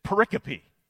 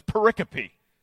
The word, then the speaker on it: pericope: It's pericope. It's spelled just like periscope, only without the S, which is what uh, a search engine will want you to do, periscope. But it's pericope.